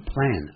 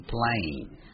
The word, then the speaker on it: plane: 飞机也是，不是念成 plan 而是念 plane 火车 train。